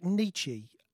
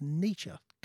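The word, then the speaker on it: Nitzsche: And I know people say Jack Nietzsche, which is an American thing. It's not. It's pronounced Nietzsche.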